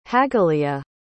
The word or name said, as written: hagaleah